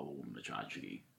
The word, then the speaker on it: tchotchke: Tchotchke is pronounced tchotchke.